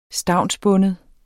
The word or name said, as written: stavnsbinde